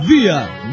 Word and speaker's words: Bier: Se pronuncia VIA. – VIA. – ¿Por qué VIA? Porque la R al final pronunciarse es una cosa rara entre la A y la R.